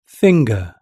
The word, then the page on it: finger: British English